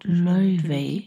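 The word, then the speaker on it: Laufey: September 25 und wie ich in der Zwischenzeit gelernt habe, Layvay wird der Name ausgesprochen.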